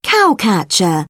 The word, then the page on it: cowcatcher: British English; əɹ